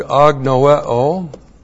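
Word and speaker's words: agnoeo (ἀγνοέω): This is the Greek word agnoeo. A-G-N-O-E, long O, agnoeo.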